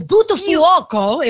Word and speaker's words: Buttafuoco: Yeah, it's Buttafuoco in Italian.